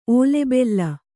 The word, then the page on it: ಓಲೆಬೆಲ್ಲ: ōlebella